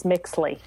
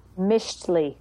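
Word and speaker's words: Mixtli: Which is very different than how I had been saying it, which was Mixly. – Okay, it's called what now? – Mishtli. – But it's French? Spelled, well, no. It's spelled M-I-X, like T-L-I.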